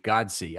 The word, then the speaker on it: Godsey: It's George Godsey. I knew I'd said it wrong. Godsey.